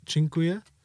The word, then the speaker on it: dziękuję: And then also, I think thank you is dziękuję.